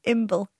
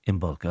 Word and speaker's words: Imbolc: Imbolc. – Imbolc, oh yeah, okay.